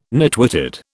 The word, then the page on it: nitwitted: British English